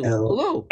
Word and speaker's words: Alu: A-L-U. How do you pronounce A-L-U? – Alou.